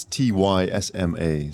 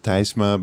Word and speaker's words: Tysma: It's T Y S M A. So you would pronounce it in Dutch. You would pronounce Tysma, but in English it's usually Tysma.